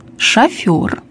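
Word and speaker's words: шофёр: Для правильной постановки ударения. Свекла, шофер, неровен час, новорожденный, скабрезный, щелочь.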